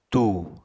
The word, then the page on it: tù: apatani